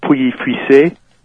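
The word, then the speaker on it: Pouilly-Fuissé: The big one is you'll hear things like Pouilly Fussy or Pouilly. I've heard it a million different ways, all of them wrong. The French pronunciation is Pouilly Fussy, but you don't really have to French it up that much.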